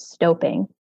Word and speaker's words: stoping: And it might be, I assume it's not stopping, but I... – You are correct. It is, the proper pronunciation is stoping.